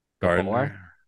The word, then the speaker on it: Gabor: voiced by Ava Garber. Wait, no, that's not how you say that. – Gabor, G A B O R. How do you say her name? Gabor. Ava Gabor.